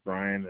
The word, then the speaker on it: Bryon: First name is Brian and last name is Arntz. First name is spelled B-R-Y-O-N. Last name is spelled A-R-N-T-Z.